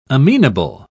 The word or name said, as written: amenable